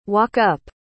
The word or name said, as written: walk-up